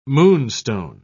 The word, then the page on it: moonstone: British English